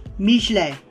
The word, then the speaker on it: Michelin: If you want to get fancy, pronounce it in its original French, Michelet.